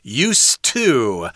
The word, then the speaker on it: used to: It's kind of interesting here because the pronunciation is used to. Uh, the D there is uh, not so soft there.